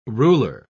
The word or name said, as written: ruler